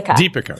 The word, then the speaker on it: Deepika: Deepika.